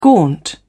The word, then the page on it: gaunt: British English